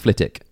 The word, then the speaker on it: Flitwick: Yeah, but it's not pronounced Flitwick. It's pronounced Flitwick. – Ah. – The W silent like Chiswick or Greenwich.